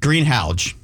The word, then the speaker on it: Halgh: It's not even close, but people get greenhouse or green hall out of H-A-L-G-H. – And it's pronounced? – Greenhouge. – Greenhouge. – Yeah, I always tell people the name Hal. You know, green, the color green, the name Hal, and the GH is like the J in jar, Greenhouge.